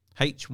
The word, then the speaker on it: hygge: Hygge, I think is how it's spelt, but it's pronounced hygge.